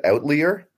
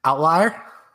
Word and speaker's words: outlier: First of all, are we really pronouncing it outlier? – Oh, man. Outlier? – Outlier. There you go. All right. I thought that might be wrong.